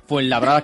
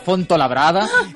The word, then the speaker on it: Fuenlabrada: Algunos se preguntarán qué tiene que ver Rafaela Carrá con una lengua muy larga, con el castellano, si ella además pronunciaba mal Fuenlabrada, que decía Fontolabrada.